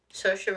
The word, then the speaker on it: Saoirse: Say it for us. – Saoirse Ronan? – That's it.